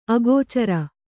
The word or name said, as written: ಅಗೋಚರ